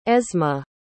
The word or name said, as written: esma